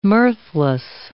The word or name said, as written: mirthless